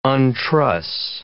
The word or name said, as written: untruss